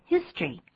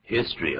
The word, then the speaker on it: history: 大家请注意 history 这个字，它原先是三个音节 history，可是一般在念的时候说成两个音节，那个 o 是不发音的 history。念作 history 就可以 history 而不要念 history 啊。